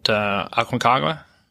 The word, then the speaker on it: Aconcagua: Aconcagua? – That's right. Hey, you did pretty good with that pronunciation.